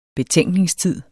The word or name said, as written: betænkningstid